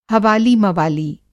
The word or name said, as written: हवाली मवाली